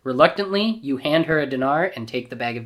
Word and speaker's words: dinar: I'm going to say it wrong. Dinar? – Dinar. – Dinar, not diner.